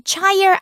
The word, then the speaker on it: choir: 그리고 성가대 노래하는 것도 choir이라고 안 해요. C-H-O-I-R인데 choir 안 하고 choir 이렇게 발음하거든요.